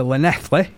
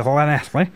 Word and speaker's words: Llanelli: They've moved all the way over to Llanelli. Have I said that right this time? – I think you didn't get it. It should have been Llanelli.